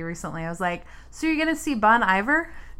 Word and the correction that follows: Bon Iver: And I was like, what, how do you pronounce it? And he was like, it's Bon Iver. And I was like, cool, so Bon Iver.